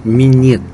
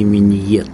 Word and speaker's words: минет: Кстати, меня уже один раз поправили, я рассказывал анекдот, оказывается, по-русски правильно говорить минет, а не миньет.